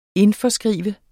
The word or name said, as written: indforskrive